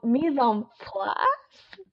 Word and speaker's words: mise en place: Okay, it's mise en mise en place.